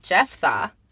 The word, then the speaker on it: Jephthah: Okay, it's spelled J-E-P-H-T-H-A-H. – Jephthah, that's what I was thinking.